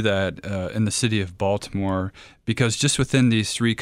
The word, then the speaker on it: Baltimore: And you had the opportunity to visit an urban ministry in Baltimore, correct? – Mm-hmm. Correct. – Or as the locals say it, Balmer.